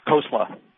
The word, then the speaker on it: Khosla: Right, Khosla. – Khosla, almost like coleslaw,